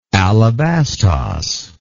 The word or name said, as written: alabastos